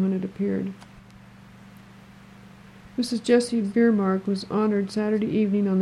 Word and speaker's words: Bjerremark: Biermark. It's spelled B-J-E-R-R-E-M-A-R-K.